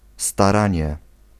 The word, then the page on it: démarche: France; [de.maʁʃ]